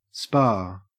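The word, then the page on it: spa: au Australian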